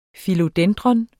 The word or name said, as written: Philodendron